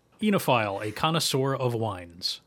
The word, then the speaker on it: oenophile: O-E-N-O-P-H-I-L-E. – Really? – Yeah. – I feel like it should be venophile. – You would think, but I believe it is enophile. O-E-N-O-P-H-P-H.